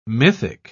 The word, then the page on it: mythic: British English